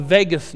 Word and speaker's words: vagus: It's called the vagus nerve, pronounced like Las Vegas, but it's spelled V-A-G-U-S.